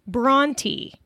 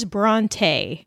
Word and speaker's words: Brontë: And I quote from the principal curator, "In Haworth, the pronunciation was and still is Brontë. However, the pronunciation usually at use with staff at the museum is Brontë. Whichever you opt for will be fine.